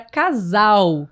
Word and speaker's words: casal: Claudine trouxe a palavra casal, que também é com S, mas com som de Z, né? – Casal.